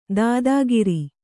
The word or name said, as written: ದಾದಾಗಿರಿ